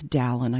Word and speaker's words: Daehlin: It's Daehlin. I should have said that.